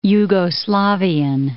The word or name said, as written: Jugoslavian